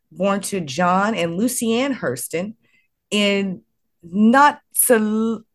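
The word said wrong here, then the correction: Notasulga: I'm saying it wrong. Notasuga, Alabama.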